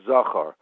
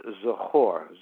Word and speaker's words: Zachor: Most people say it's Parshas Zachor. I just want to make a correction in the way it's been pronounced. It's really, the pronunciation is Zachor.